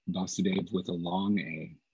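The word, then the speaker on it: Vāsudeva: It's called Vasudev, or Vasudev with a short A. So the Lord is revealed there in this Vasudev with a short A. And that, of course, is a name for Krishna's father. In the very beginning of the Bhagavatam, Om Namo Bhagavate Vasudevaya, it's given there Vasudev with a long A, referring to Krishna.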